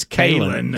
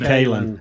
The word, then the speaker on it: Kalen: He is Karen. – Karen? – It's Kalen. – Kalen. – It's Kalen. – Kalen. – Pretty sure Kalen it's DeBoer. Karen. I'm pretty sure it's Karen. – It's Kalen.